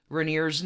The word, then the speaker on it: Rainier: This is my favorite little tidbit in how actually I remember to pronounce Rainier's name. Marilyn referred to her possible future husband as Prince Reindeer. Prince Rainier, Prince Reindeer. – Like, sure. – It's a good way to remember how you say that.